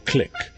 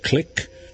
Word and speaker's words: clique: Is it clique, like the sound of a switchblade, or clique, like Klook's Clique, a London jazz club I used to infrequently frequent on occasional occasions? Well, as it turns out, either pronunciation is correct. You have the option.